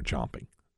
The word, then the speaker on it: chomping: But the dispute is over how that's pronounced, champing or chomping. That's where that comes in. – Right. Okay. – And both are acceptable.